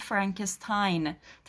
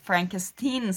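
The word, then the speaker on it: Frankenstein: فرانکستاین تلفظ میشه. انقدر این چیز فردریک از این نسبت فامیلی ناراحته که از دانشجوهاش اول فیلم میخواد که اصلا منو فرانکستاین صدا نزنین، فرانکستین صدا بزنین، و اینو در طول فیلم چندین دفعه در واقع تکرار میکنه، که خیلی کمدیای جالبی اطراف همین این تفاوت تلفظ ایجاد میشه.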